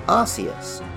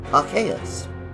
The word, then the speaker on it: Arceus: For a long time, most people pronounced it Arceus, which was especially funny in the UK, where arse is a slightly rude word for the butt. The 7th generation Pokemon Type Null and Silvally, kind of clones or copies of Arceus, clear things up with an ability called Arceus, clearly designed to resemble the pronunciation Arceus.